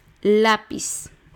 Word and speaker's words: lápiz: Lápiz, va el acento en la A, son dos sílabas, lápiz.